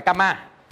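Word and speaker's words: kamma: À, mình đọc là kama. À, tiếng tiếng Anh á.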